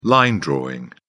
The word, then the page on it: line drawing: British English